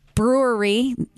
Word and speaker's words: Brouwerij: So from 2 to 5, and anybody jumping in the car that missed the beginning, it's at the Brewery West in San Pedro, but that is spelled Brouwerij.